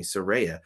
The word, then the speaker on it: Saraya: This was his his sleeper match of the night: Doctor Britt Baker DMD versus Soraya, or excuse me, Saraya Ray Saraya.